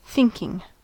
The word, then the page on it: thinking: American English